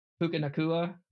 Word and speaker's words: Puka Nacua: Puka Nacua? – Yeah.